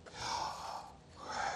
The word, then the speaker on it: Yahweh: And when you properly pronounce them, in fact, to correctly pronounce this name, you essentially breathe. As you inhale, and as you exhale, come on, let's do it together. His name is Yahweh.